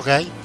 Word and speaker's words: Guy: Guy, ¿no? Se pronuncia. – Ah, bueno, Guy, sí.